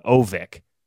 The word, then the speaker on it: Örnsköldsvik: and I was trying to learn how to pronounce Örnsköldsvik, and the Twins just told me to call it Ovik, but I didn't have that shortcut.